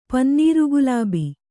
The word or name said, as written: ಪನ್ನೀರುಗುಲಾಬಿ